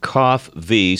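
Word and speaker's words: Coffvee: Again, it's pronounced Cough V, spelled C-O-F-F. – V-E-E. – V-E-E.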